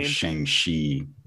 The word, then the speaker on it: Shang-Chi: So it's actually Shang-Chi, as I understand it. – I wouldn't even have got it right. I know you said Shy because of Shy Town, but because of Shy Town.